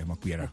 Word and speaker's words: Mokbiera: Did I say it right? – Yeah, Mokbiera.